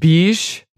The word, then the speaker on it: Bysshe: Percy Bysshe Shelley. It's pronounced Bysshe.